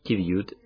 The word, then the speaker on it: qiviut: Kiviut, se escribe Q-I-V-I-U-T.